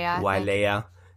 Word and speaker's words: Wailea: Beautiful, but I really hate the idea, sort of the southern part, Wailea. I'm maybe pronouncing wrong. – Wailea.